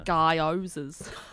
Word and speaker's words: gyoza: Oh, I normally have a few frozen gyozas in the... – Gyozas. – What? How do you say it? – It's like Guy Pearce, like gyozas.